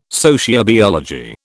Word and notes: sociobiology: British English

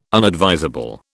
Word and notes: unadvisable: British English

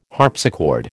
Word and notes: harpsichord: American English

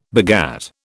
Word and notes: begat: British English

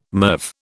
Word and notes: MIRV: British English

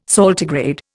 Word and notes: saltigrade: Indian

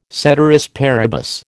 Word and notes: ceteris paribus: American English

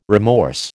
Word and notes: remorse: American English

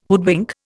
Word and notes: hoodwink: Indian

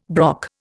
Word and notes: brock: Indian

